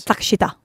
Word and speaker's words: Takeshita: And how would you say that in Japanese? – Takeshita. – Thank you. Just a little bit quicker than how I said it.